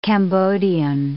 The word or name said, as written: Cambodian